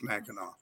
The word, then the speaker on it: Mackinac: It's spelled Mackinac, but it's it's Mackinac.